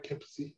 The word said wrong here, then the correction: Campisi: I'm not sure. I think it's Campisi. – Campisi sounds right.